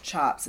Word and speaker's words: tjaps: Batiks are usually made out of chops, which is spelled T-J-A-P-S, and it's pronounced chops.